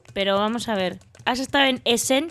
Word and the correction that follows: Essen: Que por cierto, no sé por qué le pones acento en la segunda, ¿eh? – Eso, he estado en Essen, eso es.